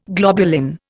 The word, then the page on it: globulin: Indian